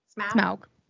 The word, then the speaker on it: Smaug: Is it Smaug? Did I say it right? – Smaug.